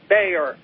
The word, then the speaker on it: Baier: Hey, I watched a special report by Brett Baier. It's spelled B-A-I-E-R.